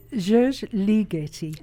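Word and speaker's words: György Ligeti: where she studied with the legendary Giorgi Ligeti. Is that the right pronunciation? – Giorgi Ligeti. – Oh, Ligeti, okay.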